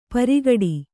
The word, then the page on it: ಪೆರ್ಗಟ್ಟ: Kannada